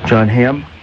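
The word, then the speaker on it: Jon Hamm: And my guest today is John Hamm.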